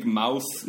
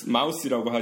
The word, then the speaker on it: mouth: 이렇게 발음을 조심하셔야죠. big mouth, mouse라고 하시면은 큰 귀 기운다.